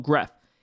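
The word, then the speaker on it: Grefg: The G is silent, so we call him Gref.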